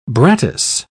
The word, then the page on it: brattice: British English